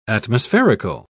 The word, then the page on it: atmospherical: British English